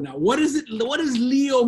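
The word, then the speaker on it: lío: Leo. L-I, accent on the I, O. It's not your zodiac sign.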